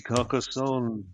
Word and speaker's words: Carcassonne: Several places got mentioned, but I think through sheer democracy, I must go with, if hopefully I'm pronouncing this right, is it Carcassonne in the south of France? – Oh, it is indeed Carcassonne.